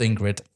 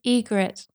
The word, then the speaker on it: Ygritte: I loved Ingrid. – Her name is not Ingrid, it's Ygritte.